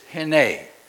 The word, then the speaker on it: hinneh: Now the reason that's so important is that Hebrew word is hine. Phonetically it's spelled H-I-N-N-E-H with a long E for those that are listening.